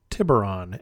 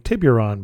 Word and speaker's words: Tiburon: Last week's show, I gave you an update on my travels, and apparently I mispronounced Tiburon as saying Tiburon because there's a U in there that I'm not supposed to pronounce.